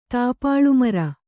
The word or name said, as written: ತಾಪಾಳುಮರ